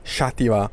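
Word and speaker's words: Xàtiva: We stayed in a place called Chatiwa. All the names here are in Valencian dialect, which is very similar to Catalan, so it begins with an X, very difficult to pronounce.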